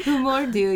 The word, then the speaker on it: Hur mår du: Hur mår du? Did I say it right? – Hur mår du, yeah.